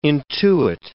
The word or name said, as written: intuit